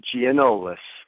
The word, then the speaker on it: Gianoulis: Gianoulis.